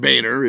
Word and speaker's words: Boehner: Could have taken a page out of the John Boehner playbook. Of course, Boehner and Boehner is way different than Weiner and Weiner.